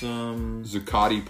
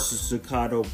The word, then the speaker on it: Zuccotti: It's Zuccotti Park. – Zuccotto Park.